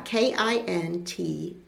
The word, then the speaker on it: Kintsugi: Kintsugi? – I think it was Kintsugi, right? – Yeah. Kintsugi.